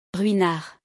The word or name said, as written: Ruinart